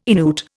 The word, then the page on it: Innuit: Indian